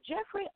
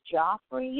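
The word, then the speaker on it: Geoffrey: And uh Jeffrey Owens, I think that's how you pronounce his first name, or is Joffrey? You know how it's G E O F F R E Y. I'm gonna say Jeffrey. How's that?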